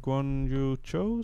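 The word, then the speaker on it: Guanyu Zhou: Eh, goed, dan eh, tot slot de 5 seconden straf voor Yuki door het naar buiten duwen van Guan Yu Cho, zeg ik dat goed? Ja.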